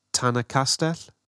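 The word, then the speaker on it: Tan-y-Castell: Tannycastle. Am I saying that right? – Do you mean Tannycastell? – Tannycastell.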